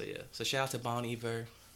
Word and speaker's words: Bon Iver: The second thing was we mispronounced Bon Iver's name. Apparently, it's Bon Iver.